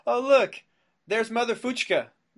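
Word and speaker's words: Fučka: And his name was Gregor Fuchka, but it's spelled F-U-C-K-A.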